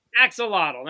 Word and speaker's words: axolotl: Axolotl, thank you.